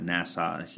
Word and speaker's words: NASA: And I want to bring you a really great conversation I had with Terry Fong over at NASA. I say it like Nassau County. It's really NASA, as people who are smarter than me say it.